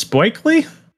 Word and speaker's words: Buechele: Shane Buechele for the Chiefs. Buechele? – Buechele.